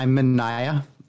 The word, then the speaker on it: Mineya: I'm Mineya. So you did get that correct.